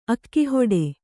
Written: ಅಕ್ಕಿಹಿಟ್ಟು

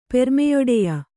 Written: ಪೆರ್ಮೆಯೊಡೆಯ

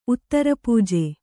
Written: ಉತ್ತರಪೂಜೆ

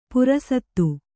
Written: ಪುರಸತ್ತು

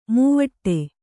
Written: ಮೈವಡೆ

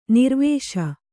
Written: ನಿರ್ವಿಷ

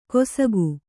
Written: ಕೊಸಕು

